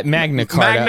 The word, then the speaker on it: Magna Carta: It's like Paul's Magna Charta of freedom. Did I say that right? What is it? – Magna Carta. Magna Carta.